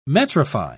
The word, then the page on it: metrify: British English